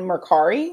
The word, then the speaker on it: Mercari: She sells on Poshmark, but she sells more on Mercari.